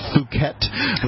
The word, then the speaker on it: Phuket: But in fact, the name of the city in Thailand, and it's spelled with PH, Phuket.